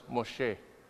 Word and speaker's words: Moshe: And as you can see, his name in Hebrew is pronounced Moshe.